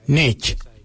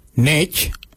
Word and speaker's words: ngeidj: A-N-G. Nganyang. Nganyang means give me. For I, we say nich. Nich, it's spelled N-G-E-I-D-J.